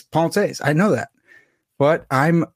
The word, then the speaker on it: Pensées: I know that it's Pensées. I had Guillaume Bignon on to tell me that.